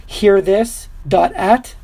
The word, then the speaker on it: hearthis.at: There's a website called hearthis.at. Did I say it right, Decade? – Yes.